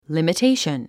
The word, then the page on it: limitation: lìmitéiʃən; リミテイション